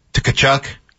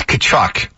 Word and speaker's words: Tkachuk: That shot that Tkachuk knocked in. – No, it's Tkachuk. – Tkachuk knocked in. – You just want to pronounce that T, don't you? – The shot that Tkachuk knocked in.